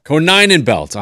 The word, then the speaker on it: Konyenbelt: You do a Ko, the number nine, a Nen, and a belt. So it's Konyenbelt. – Konyenbelt.